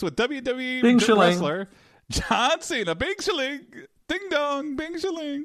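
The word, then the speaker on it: Bing Shiling (冰淇淋): How do you say, what's the word he says in Mandarin, that's ice cream? – Oh, Bing Shiling. – What is it? – Bing Shiling. – Bing Shiling, that's right.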